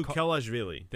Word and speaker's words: Mamukelashvili: Sandro Mamukelashvili, I think that's how you say his name. Mamukelashvili.